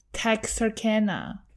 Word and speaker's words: Texarkana: I googled how to say this word, but it's been too long that I forgot. Texana? Texerkana. Pronunciation. Texarkana. There we go.